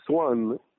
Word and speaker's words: أسوان: أسوان في اللغة المصرية القديمة بتنطق سوان، وسوان بتعني السوق. ولماذا السوق؟ لأنه أسوان